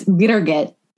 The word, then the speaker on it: Birgit: It's pronounced beer gut with a lot of mess in the middle is how she taught me to say it. So it's Birgit, but it took like a year to figure out how to say it properly.